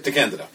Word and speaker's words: DeCandido: DeCandido, depending on how you pronounce it. – DeCandido.